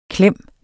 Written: klem

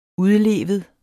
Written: udlevet

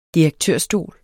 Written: direktørstol

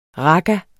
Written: raga

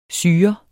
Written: syrer

